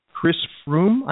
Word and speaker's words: Froome: And then the other thing that I wanted to bring up in terms of the cycling sports front, Chris Froome, I think is how you say his name. – Yeah.